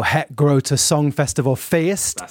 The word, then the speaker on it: Het Grote Song Festival Feest: We were both in Amsterdam for Het Grote Song Festival Feest, which I think is how we've pronounced it. – You've done it right. It's the Festival Feest.